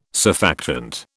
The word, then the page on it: surfactant: British English